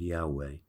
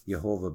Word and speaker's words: Yahweh: Sometimes people want to pronounce it Jehovah, but that is not as accurate, actually, from all the studies that reveal Yahweh is the most accurate word for the name of God.